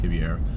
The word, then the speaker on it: Ribeiro: If you don't know who Vitor Shaolin, it's Ribeiro is how it's spelled, but it's pronounced with an H, Ribeiro.